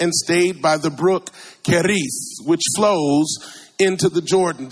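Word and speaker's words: Cherith: And by the way, that's not a bad pronunciation, Keris. Yeah, you got to get, you got to roll your R on that.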